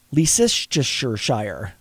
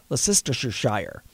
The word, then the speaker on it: Leicestershire: In July, Crane, 49, was singing along to the radio while working on a job in Leicestershire. Oh boy, I'm gonna butcher this name. It's in England. – Okay. – Leicestershire. It's like Worcestershire. – Yeah. – Leicestershire.